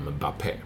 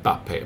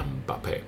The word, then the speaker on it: Mbappé: Att de flesta i Frankrike uttalade Mbappé. Man kan säga Bappé, men de flesta säger det Mbappé. Så i studion sa de Mbappé.